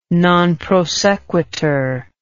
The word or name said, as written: non prosequitur